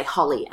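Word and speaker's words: Hollie: And I say, Holly, and I don't tell them that it's spelt with an IE.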